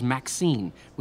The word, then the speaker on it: MXene: It's a material he calls Maxene, which is spelled M-X-E-N-E.